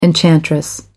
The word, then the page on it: enchantress: American English